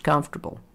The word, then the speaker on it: comfortable: And you can also take a word like comfortable. Notice it's spelled comfortable, but it's pronounced comfortable. We have a lot of those in English.